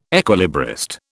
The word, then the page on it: equilibrist: British English